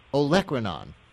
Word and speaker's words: olecranon: I pronounced this one wrong, by the way, in promoting the segment. I won't give my incorrect pronouncer again. I'll just give you the right one. Olecranon.